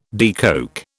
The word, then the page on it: decoke: British English